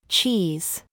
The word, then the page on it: ZOOS: American English